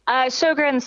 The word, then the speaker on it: Sjögren's: Sjögren's syndrome, yes.